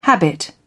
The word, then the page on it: habit: British English